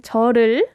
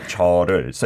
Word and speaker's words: 절을: Okay, so 절 meaning 절을. – That's like combined those two syllables. It's not 절 as in bow, which could be confusing for our listeners. But 절을. So you could say 절을 as well.